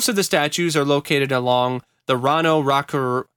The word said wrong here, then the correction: Raraku: uh, Raraku. Sorry, I, I'm I'm butchering this this language to pieces here.